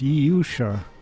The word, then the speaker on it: Yusha: You're listening to The New Abnormal here on WPRB Princeton, where we just heard from the Norwegian symphonic prog band Yusha. It's spelled like Yordsio, but I think it's pronounced Yusha. This is their most recent recording.